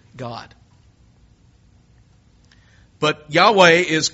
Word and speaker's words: Yahweh: And it may have been pronounced something like Yahweh.